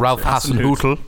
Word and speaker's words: Ralph Hasenhüttl: So he said he reckons that their manager, who I forget his name now because I can't pronounce Ralph Hasenhüttl.